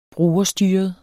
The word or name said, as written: brugerstyret